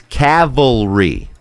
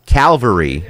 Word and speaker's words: cavalry: No, the L is after the V. So most people pronounce it Calvary. – Yeah. – Like, okay. – Here comes the Calvary. – Right. – But it's, here comes the Cavalry. – Right. – It's C-A-V.